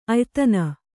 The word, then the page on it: ಆಯಿದೆನೆ: āyidene